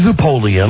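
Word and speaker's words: Zapoleon: When we come back, we're going to talk about, and again, the guy's name is Guy Zapolian. – Rhymes with Napoleon.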